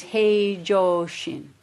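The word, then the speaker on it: hei joshin (平常心): The original Chinese for this phrase, ordinary mind, ordinary, for ordinary mind is hei joshin. I only know how to pronounce in Japanese.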